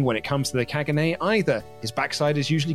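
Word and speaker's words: Caganer: It is pronounced Kagane.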